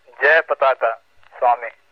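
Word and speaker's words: Jayapataka Swami: Yes, and by the way, Joe, I think his name should be pronounced Jay Pataka Swami.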